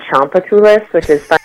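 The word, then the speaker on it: Tchoupitoulas: Most of the way up and down New Orleans called Champatoulis, which is spelled wonderfully, but his name is Champatoulis.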